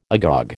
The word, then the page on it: agog: American English